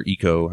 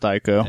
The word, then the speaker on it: Ico: Anyway, the game that this team did before Shadow of the Colossus was called Ico, or Eco, however you say it. – I've always heard it pronounced Ico.